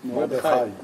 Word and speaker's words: Mordechai: Is it Mordechai or Mordechai? – Mordechai. – What's the... – It depends. You see, It depends. – Persians, the way they pronounce, because it's Iranian name. – Officially, in the Hebrew language, is it with a patach or a kamas?